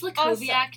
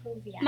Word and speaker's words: Lukowiak: Yes, yeah, it's Lukowiak, technically, but like... Lukowiak. My grandpa recently told me that he thinks it's Lukowiak, and then I had a crisis because I was like, "Grandpa, what?